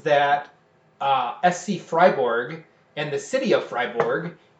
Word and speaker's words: Freiburg: Freiburg is the way that you speak it in German. – Hmm. – In English, it would be Freiburg, but it's Freiburg in German.